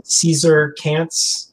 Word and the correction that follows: Cesar Canyes: Cesar Canyes, yeah. – Canyes, yeah.